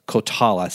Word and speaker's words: Coltala: I want to make sure I'm pronouncing that right. It's Coltala, C-O-L-T-A-L-A Holdings, which is a private equity firm headquartered in Fort Worth.